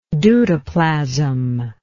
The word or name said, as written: deutoplastic